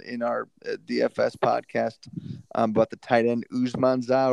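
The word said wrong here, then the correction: Uzomah: or whatever, however you say his name. – CJ Uzuma. – Yeah, Uzuma.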